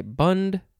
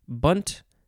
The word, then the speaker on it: Bund: This is, you could say bund, bund, bunt. A few ways to pronounce it.